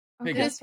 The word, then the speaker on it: Piggot: But no, uh, so I looked up the name so we pronounce it correctly. Apparently, it's pronounced Pigot, Piggot. Oh, Piggot. Really? Piggot. Okay. – Okay, Is how it's usually pronounced.